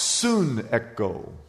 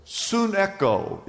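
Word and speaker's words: sunecho: S-U-N, like the sun, and then echo. It's pronounced sun echo. Sun echo is the Greek word for compel.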